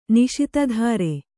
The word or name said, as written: ನಿಶಿತಧಾರೆ